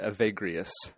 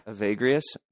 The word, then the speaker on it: Evagrius: So I'm going to pronounce it Evagrius. – Evagrius? Okay.